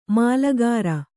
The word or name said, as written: ಮಾಲಗಾರ